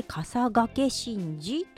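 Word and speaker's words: 笠懸神事: 10 月 15 日日曜日にあります上賀茂神社で行われる笠掛神事です。はい、笠掛ってねちょっと難しい字を書くんですが、頭にかぶる笠ですね、竹冠に立つって書く笠に、かけるは一生懸命の剣ですねって書いて笠掛神事と読むんだそうです。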